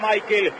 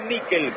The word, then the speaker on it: Mikel: ¿Cómo le decimos? ¿Michael o Mikel? Nicolás Álvarez, usted que es especialista en nombre, se escribe Mikel, ¿cómo le digo? – Decirle Mikel. – Entonces le digo a Mikel, usted me enseña y yo aprendo.